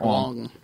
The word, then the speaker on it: Wang: Garrett Wong. Even though it's spelled Wang.